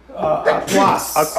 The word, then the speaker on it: Artois: How do you say Artois. – It? – Artois.